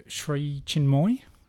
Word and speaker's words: Sri Chinmoy: And so if we move on now to the Sri Chinmoy 24-hour, yes. – Is that the way you pronounce it? – That's right.